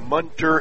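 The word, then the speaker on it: Moenter: correct pronunciation, more like Munter, as if it were a U, M-O-E-N-T-E-R.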